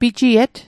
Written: pijiet

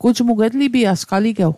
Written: etlipiasg

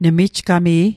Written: nemijgami'

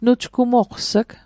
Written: nujgmoqs'g

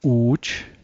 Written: u'j

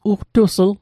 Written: ugtusl

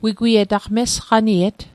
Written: mesqaniet

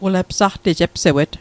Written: epsewet

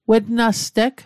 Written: wetnasteg

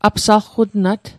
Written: apsalqutnat

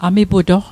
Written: amiputoq

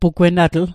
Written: pugwennatl

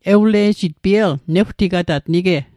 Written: newtigatat

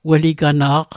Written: weligana'q

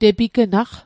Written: tepignaq

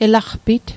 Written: elaqpit

